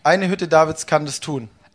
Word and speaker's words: Hütte Davids: You call it Hutz David, but how do you pronounce it? – Okay. Die Hütte Davids kann das tun. – Hutte David.